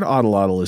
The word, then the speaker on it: atlatl: So the word Odolotl is spelled A-T-L-A-T-L.